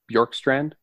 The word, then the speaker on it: Bjorkstrand: Next guy I want to talk, we're going to talk about Oliver Bjorkstrand. I'll do my best to hoping that I'm pronouncing his last name correctly. – Yeah, I think that's correct.